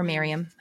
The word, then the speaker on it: Mariamne: So after this, Herod then begins to grow suspicious of his wife, Mariamne, or Miriam, how it's pronounced both ways.